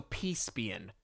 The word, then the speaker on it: Caspian: Do you know I didn't know which one was Miraz and which one was Saprissian? – Yeah. Yeah. – Or so Caspian. Caspian is his name.